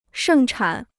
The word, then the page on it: 盛产: shèng chǎn